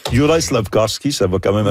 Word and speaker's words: Juraj: Personnellement, moi, j'ai de la difficulté avec Jurav Slavkovski. Oui, Juraj.